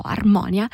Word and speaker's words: Armonia: E allora ha scritto un disco, si chiama Armonia, così, con l'accento sulla O, Armonia, e